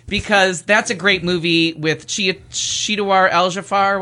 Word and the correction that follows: Chiwetel Ejiofor: Chiwetel Ejiofor.